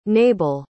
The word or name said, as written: nebel